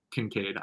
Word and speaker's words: Kincaid: Kinsiad, if that's how you say his name Kincaid, Kinsiad. I think. – Well, uh, Kincaid. I knew I was saying it wrong.